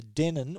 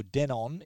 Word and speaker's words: Denon: The Denon or Denon, if you want to pronounce it that way,